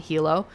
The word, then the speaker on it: Hilo: A 29-year-old person visiting from Vermont was arrested and charged for disorderly conduct and harassment after an incident at a bar in Hilo. I also think that's how you say it, H-I-L-O.